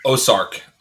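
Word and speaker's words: Ossiarch: Ossiark. – Ozark. Ozark, like the show.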